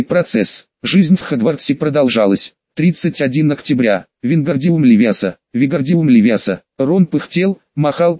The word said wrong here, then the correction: Левиоса: Правильно говорить с ударением Левиоса, а не Левиаса.